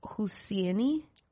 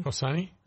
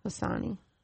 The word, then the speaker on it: Hosseini: It's by, Khalid Husseini. – Hussein? – I don't think it's Hussein. – How do you spell that last name? – Hosseini. – Hosseini.